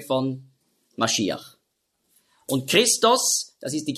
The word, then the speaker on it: Maschiach: Christus ist einfach die Übersetzung von Hebräisch Maschiach, und Messias, das ist einfach die Aussprache, die Griechen konnten nicht Maschiach sagen, weil es gibt ja kein Sch-Laut im Griechischen. Darum sagen auch die modernen Griechen, die sagen ja nicht Fisch, sondern Fis. Außer sie haben schon gelernt. Also darum wurde aus Masch, wurde Mess, und dann Iach mit dem Het am Schluss, Maschiach, hat man früher ausgesprochen, Maschiach. Da hatten sie auch Mühe, und darum haben sie daraus gemacht Messias.